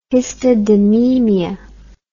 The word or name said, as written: histidinemia